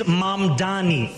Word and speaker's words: Mamdani: And furthermore, the name is Mamdani. M-A-M-D-A-N-I. You should learn how to say it because we got to get it right.